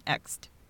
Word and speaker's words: Ecsed: And it's spelled E-C-S-E-D, but it's pronounced Ext.